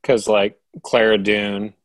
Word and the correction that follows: Cara Dune: am I saying that right? – Cara. – Or Cara Dune.